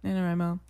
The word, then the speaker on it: NaNoWriMo: NaNoWriMo.